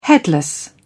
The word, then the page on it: headless: British English